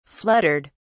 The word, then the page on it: fluttered: British English